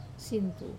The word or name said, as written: sinitu